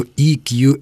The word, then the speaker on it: EQ: Только я дополню, что EQ – это если произносить, правильно называя буквы английского языка. Под буквой «и» подразумевается та буква, которая по-русски пишется как «е» – «emotional».